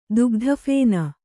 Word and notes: ದುಗ್ಧಫೇನ: dugdha phēna

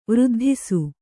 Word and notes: ವೃದ್ಧಿಸು: vřddhisu

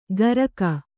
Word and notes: ಗರಕ: Kannada

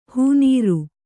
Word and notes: ಹೂನೀರು: hū nīru

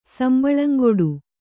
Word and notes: ಸಂಬಳಂಗೊಡು: Kannada

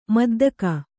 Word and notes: ಮುದ್ದಿಗೆ: Kannada

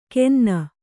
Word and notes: ಕೆನ್ನ: kenna

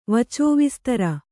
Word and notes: ವಚೋವಿಸ್ತರ: kannada

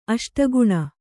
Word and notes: ಅಷ್ಟಕೋಣ: aṣtakōṇa

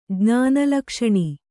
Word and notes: ಜ್ಞಾನಲಕ್ಷಣಿ: kannada

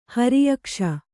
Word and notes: ಹರಿಯಕ್ಷ: Kannada

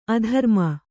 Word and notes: ಅಧರ್ಮ: adharma

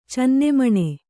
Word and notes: ಚೆನ್ನೆಮಣೆ: cenne maṇe

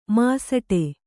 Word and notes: ಮಾಸಟೆ: māsaṭe

